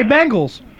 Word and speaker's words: Bengals: Hey, what's the second letter in the word Bengals? – E. – How do you pronounce the letter E? – E. – So now say Bengals. Say it.